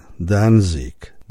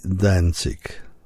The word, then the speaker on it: Danzig: Otóż artysta, który wystąpił przed chwilą, nazywa się Glenn Danzig, bo jest przecież Amerykaninem, a ja zastosowałem wymowę z wolnego miasta Gdańska, czyli Danzig. Niesłusznie. Glenn Danzig wystąpił.